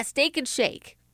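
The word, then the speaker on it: Steak 'n Shake: Yeah, Steak and Shake.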